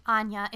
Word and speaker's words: Aine: Like, I do it all the time at the movie theater because, like, my name, Anya, is not spelled like a lot of spellings of Anya, which is A-N-Y-A. It's spelled, it doesn't look anything like how it's pronounced. It's A-I-N-E.